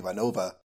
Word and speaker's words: Ivanova: So Ivanova, if she were pronouncing it more according to Russian pronunciation rules, would be Ivanova, and Takashima would be Takashima.